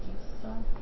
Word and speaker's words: Esau: Esau.